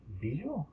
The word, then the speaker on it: Bagieu: Bijou. B-A-G-I-E-U.